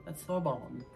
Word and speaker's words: Sorbonne: Sorbonne. – And I know I'm saying it right because I've looked up the pronunciation.